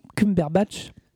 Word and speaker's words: Cumberbatch: alors j'ai prononcé ça très très mal parce concombre Batch. Ouais, concombre Cumberbatch.